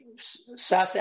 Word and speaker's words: Sasse: I just covered intensely in the last couple of days the browbeating that the Sasse, how do you say his name? Sasse? – Ben Sass. – Sass, yeah.